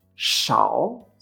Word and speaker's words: shao: fecha, Por ejemplo, S-H-A-O en tercer tono se pronuncia shao, shao.